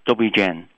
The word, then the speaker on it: Dobrý deň: Good day is Dobrý deň.